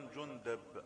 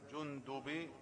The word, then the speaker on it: جندب: أما الراوي الأول فهو أبو ذر جندب ابن جنادة، جندب ابن جنادة، وينطق أيضا جندب بفتح الدال وجندب بكسرها. يبقى إذن الدال مثلثة، مثلثة يعني تضم وتفتح وتكسر. يقول: عن أبي ذر جندب